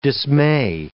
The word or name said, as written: dismay